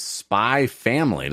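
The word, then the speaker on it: Spy × Family: That is Spy Family. Is Spy X Family? Like Spy Cross Family? – Yeah, but I've never heard anyone pronounce the X or cross, so I stopped doing it.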